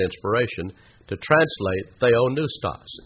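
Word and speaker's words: theonoustos (θεόπνευστος): Now, in Greek, that word inspired is a compound Greek word, theonoustos. Theos, you already know what that means, don't you? Theos, God. Noustos, spelled with a P-N-E-U, like pneumatic or pneumonia.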